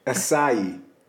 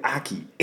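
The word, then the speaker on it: açaí: Acai. Like the acai bowls. – Right. – I used to call it aki.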